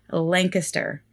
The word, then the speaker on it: Lancaster: And she let me know that during the Jonathan Luna episode, I pronounced it Lancaster, and that is something that makes the locals cringe. So thank you so much, Kathy, and I will do my best to remember to say Lancaster from now on.